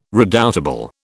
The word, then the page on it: redoubtable: British English